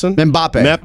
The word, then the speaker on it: Mbappe: M-B-A-P-P-E. Kylian Mbappe. – Yeah. – Mbappe.